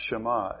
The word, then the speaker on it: Shema: In the Hebrew, I believe it's pronounced Shema. S-H-A-M-A is the English spelling.